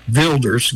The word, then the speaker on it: Wilders: It's spelled Wilders, but is pronounced Wilders, Geert Wilders.